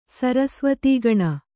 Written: ಸಾರಸ್ವತಗುಣ

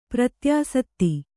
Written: ಪ್ರತ್ಯಾಸತ್ತಿ

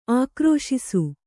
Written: ಆಕ್ರೋಶಿಸು

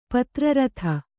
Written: ಪತ್ರರಥ

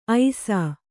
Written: ಐಸು

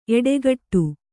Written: ಎಡಗಡೆ

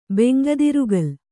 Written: ಬೆಂಗದಿರುಗಲ್

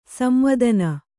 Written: ಸಂವೇದನ

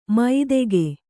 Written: ಮೈದೆಗೆ

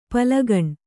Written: ಪೀಲಿಗಣ್

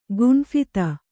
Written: ಗುಂಫಿತ

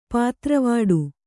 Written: ಪೊದರ್ವಡೆ

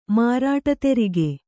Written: ಮಾರಾಟತೆರಿಗೆ

